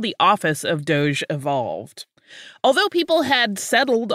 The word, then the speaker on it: Doge: Yeah, and in Italian, that final E is pronounced a little bit more, but in English, we say it Doge.